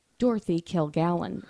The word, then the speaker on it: Kilgallen: Dorothy Kilgallen? – Dorothy Kilgallen, as Google pronounces it.